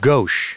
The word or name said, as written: gauche